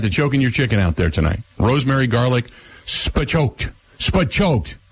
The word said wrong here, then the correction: spatchcocked: Is that how you say it, Grant? – Spatchcocked.